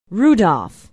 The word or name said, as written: Rudolph